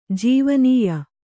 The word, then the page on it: ಜೀವನೀಯ: Kannada